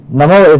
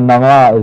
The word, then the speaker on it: Namo: Hail, Om, Namo is name or Nama is name. It depends on how you want to pronounce it, N-A-M-O or N-A-M-A, but it's Namo in the chant itself.